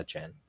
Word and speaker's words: Achane: and they said Achane, so that's what I always said, and then now it's A Chan, and I just everything I thought was a lie.